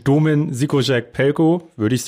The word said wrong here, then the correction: Domen: Ich ja, da also also ich sage mal Domen Pelko, ich sage einfach nur Domen, das ist richtig.